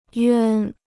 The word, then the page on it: 冤: yuān